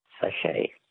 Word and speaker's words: sachet: Words that end with a silent T. Which word for a small sealed pouch literally means little sack in French? – Sachet. – A sachet. S-A-C-H-E-T. Well,